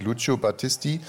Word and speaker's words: Lucio Battisti: Es gibt es Lucio Battisti, Il mio canto libero. – Warte mal. Also ich finde, das ist relativ gut ausgesprochen, weil es heißt wirklich Lucio Battisti.